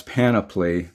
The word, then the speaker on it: panoply: God, put on God's panoply, rhymes with canopy, so that you are able to withstand the slanderer's wiles.